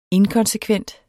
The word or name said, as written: inkonsekvent